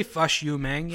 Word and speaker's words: Fush Yu Mang: I say Fush Yu Mang, yeah. – Fush? – Fush? No, because it's like Fush Yu Mang, like it's a drunken Al Pacino, yeah.